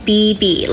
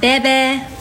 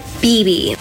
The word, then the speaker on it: Bebe: My name is Bebe, like two B's, not Bebe, Bebe.